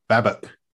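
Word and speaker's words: BABOK: The BABOC, which I think is probably the best one I've heard. I typically call it the BA BOC. – The BA BOC, that's what I hear.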